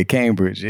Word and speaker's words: Cambridge: Yeah, Cambridge.